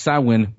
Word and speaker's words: Samhain: But he's named Sam because of that, because it's pronounced Samhain, but it's spelled Samhain, Samhain.